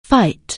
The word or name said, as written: fight